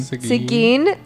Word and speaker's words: Seguin: So, this hotel is located within the city of Seguin. I don't know if I'm saying it right. – Seguin, Texas. Thank you for correcting me.